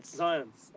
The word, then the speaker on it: Zionce: I said it right? – Zionce. – Okay, Shloime Zionce. So we have a correction, Shloime Zionce.